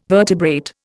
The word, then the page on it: vertebrate: Indian